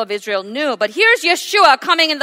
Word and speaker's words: Yeshua: Yes, in English, Jesus, but in the Aramaic, the original pronunciation, it's Yeshua,